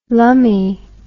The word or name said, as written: lumme